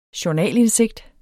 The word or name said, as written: journalindsigt